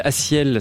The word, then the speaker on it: A6EL: Il s'appelle Asiel, ça s'écrit A6, le chiffre EL, A6EL, extrait d'un album qui s'appelle, c'est pas un album, c'est un single qui s'appelle Soleil.